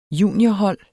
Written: juniorhold

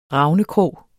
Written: ravnekrog

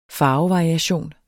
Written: farvevariation

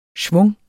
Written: schwung

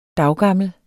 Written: daggammel

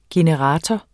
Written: generator

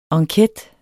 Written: ENQUETEN

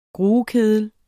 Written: gruekedel

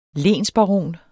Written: lensbaron

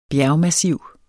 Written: bjergmassiv

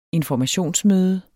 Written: informationsmøde

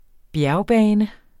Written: bjergbane

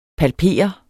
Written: PALPERET

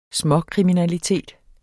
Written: småkriminalitet

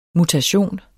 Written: mutation

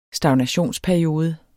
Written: stagnationsperiode

